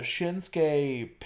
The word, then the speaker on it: Shinsuke: Shinsuke, it's two syllables. – Is not that bad. But I get Shinsuke pinned Baron Corbin.